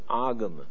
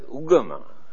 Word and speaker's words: agama: Even 2000 years ago in a Buddhist book we used this word agama, religion. It's a very meaningful word. Here now they pronounce as ugama, but the original word is agama.